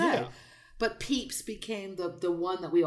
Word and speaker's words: Pepys: Right, it's P-E-P-Y-S. – Yeah. I heard about Samuel Pepys, which would obviously be spelled P-E-E-P-S, like the little, you know, soft Easter candy.